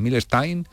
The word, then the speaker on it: Milestone: algunos lo pronuncian Milstein, que, era como en realidad, eh, era lo más parecido a su, a su apellido en origen,